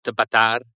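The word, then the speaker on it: Batàr: what what we did, what what they did was um, they they took away the D they put the accent on the A. It's uh, still pronounced Batar.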